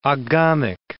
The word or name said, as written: oghamic